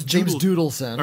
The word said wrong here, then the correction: Duddleson: Or Duddleson, I believe, is actually how it's pronounced.